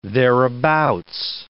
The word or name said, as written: thereabouts